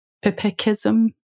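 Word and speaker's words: pipikism: And also another term that I found really useful that you borrow from Philip Roth's novel, Operation Shylock, is pipicism. Is that how you would pronounce it? Pipicism? – Pipicism, yes.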